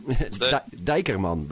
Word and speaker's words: Dijkerman: And your last name is like Dijkerman. – Dijkerman, but yeah. – Dijkerman. – Yeah. Well, you do the pronunciation pretty good.